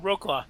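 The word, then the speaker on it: Wrocław: Is it just Roclaw? – Roclaw, yeah. – Oh. Well, that was easy. There's a W at the beginning of it, folks.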